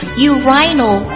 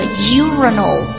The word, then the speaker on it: urinal: urinal 或者 urinal 两种读音都可